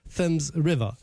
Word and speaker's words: Thames: Sì, perché la Thames River in inglese si pronuncia Thames River.